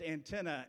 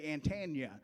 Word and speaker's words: antenna: I would come from West Virginia to Florida, and I would say words like that are supposed to be pronounced antenna, and I would say Antania, right?